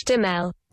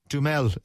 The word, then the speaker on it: Duhamel: I looked it up. Josh Duhamel on the internet. How did it pronounce it? – Josh Duhamel. – Duhamel is how you say it.